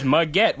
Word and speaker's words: Muggett: His name is Muggett, which rhymes with baguette.